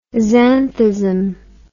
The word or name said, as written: xanthism